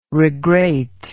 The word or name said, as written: regrate